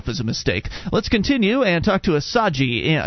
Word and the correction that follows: Asagai: Did I get it right? – No, it's Asagai. – Asagai.